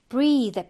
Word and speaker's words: breath: 그 다음에 breathe 발음을 좀 주의해야 되고요. breathe 이로 끝나죠. 그럼 동사로 숨을 쉬다인데요. 명사는 끝에 이가 없어. 그 발음도 breath 좀 달라요. 그래서 얘가 숨, 호흡이 되고 동사는 breathe라고 발음을 합니다.